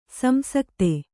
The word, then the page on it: ಸಂಸಕ್ತಿ: samsakti